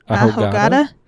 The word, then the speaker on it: ahogada: Ahogada? A-H-O-G-A-D-A.